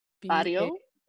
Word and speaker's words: Barrio: But there's this one called Nena, N-E-N-A, so good. And then another one called, I don't want to mispronounce it, but B-A-R-R-I-O, Barrio.